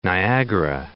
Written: Niagara Falls

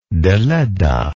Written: deledda

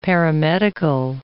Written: paramedical

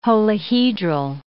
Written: holohedral

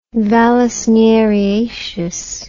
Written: vallisneriaceous

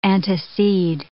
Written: antecede

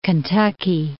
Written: Kentucky

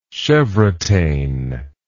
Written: chevrotain

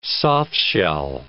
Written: softshell

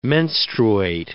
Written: menstruate

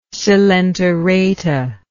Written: coelenterata

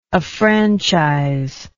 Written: affranchisement

